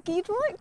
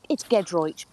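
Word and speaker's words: Giedroyc: Is it Gedroych? Did I say it right? – Listen, Emily, it's Gedroych, but you were close.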